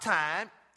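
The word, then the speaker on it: time (τιμή): This word honor in the Greek is the word time. T-I-M-E. Now, it's not pronounced time.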